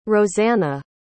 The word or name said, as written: roseanna